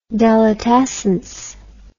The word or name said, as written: delitescence